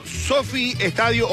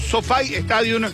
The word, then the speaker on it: SoFi Stadium: Nada más y nada menos que desde el estadio Sofi Stadium o Sofi Stadium, dependiendo de cómo uno quiera pronunciar,